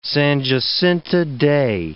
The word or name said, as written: San Jacinto Day